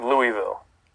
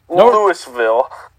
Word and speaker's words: Louisville: They play Boston College, Florida State, and Louisville. – God, you say it wrong. It's Louisville. – Louisville.